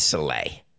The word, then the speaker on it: Saleh: And Salug, it would be, is it Salug? Am I really pronouncing his name wrong? I always thought it was Saleh. I'm sorry. It is Saleh.